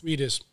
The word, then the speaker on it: Reedus: Reedus.